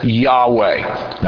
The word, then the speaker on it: Yahweh: In the original Hebrew, or actually not in Hebrew, in English transliteration of Hebrew, the name that God announces is written as YHWH. We pronounce that Yahweh.